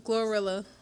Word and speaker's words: Glorilla: Isn't it Gorilla? Glorilla. I always say her name wrong. Glorilla.